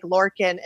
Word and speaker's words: Laurken: For those of you listening in, you've got to go to Larkin, L-A-U-R-K-E-N. And Larkin, am I pronouncing that correctly? – Yes, it is. My mother spelled it horribly. It's spelled Larkin, or it's pronounced Larkin, L-A-R-K-I-N, but it's spelled like Larkin.